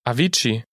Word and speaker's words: Avicii: Avicii.